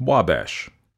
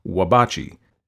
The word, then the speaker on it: Wabash: And although the spelling proves a stumbling block to some, it is quite simply pronounced Wabash. In spite of this very simple program, the locals, even more so perhaps than visitors, delight in the somewhat dignified, if erroneous, pronunciation Wabachi,